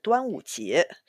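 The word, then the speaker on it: Duanwuji (端午节): Es ist Drachenbootfest, Duanwuji. – Genau, exakt. Und so spricht man das auf Chinesisch aus.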